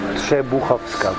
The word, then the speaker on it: Trzebuchowska: Trzebuchowska.